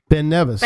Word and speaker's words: Ben Nevis: Well, that's how you say it. – Ben Nevis. – Ben there Nevis. – You there you go. Perfect.